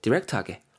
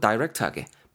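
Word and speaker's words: direct: 뭐 흑수저, 금수저, 은수저 막 따지고 하는데 포인트는 그 대시라는 게 빚이란다는 거고 그래서 그런 소재에 대해서 간접적으로 얘기하는 게 아니고 디렉트하게 혹은 다이렉트하게 발음 두 개 다 됩니다.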